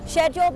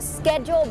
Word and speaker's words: schedule: งงออกเสียงว่า schedule หรอออกได้นะคะว่า schedule หรือว่าจะเป็น schedule ก็ได้ค่ะ schedule แบบอังกฤษนะคะ schedule แบบอเมริกัน